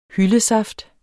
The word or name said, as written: hyldesaft